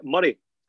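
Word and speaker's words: Moray: And the reason why Angus and I get on so well is because Angus used to represent Murray, as you thankfully pronounced correctly in the podcast.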